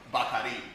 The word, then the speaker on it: Bacardi: So, well, one, they don't even, it's not Bacardi, it's Bacardi is how they pronounce it out there.